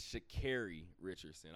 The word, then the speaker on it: Sha'Carri Richardson: It's Sha'Carri Richardson. I want to make sure that I correct myself on that. I was upset I didn't do it last episode. So shout out to to Sha'Carri Richardson as well for continuing to make history.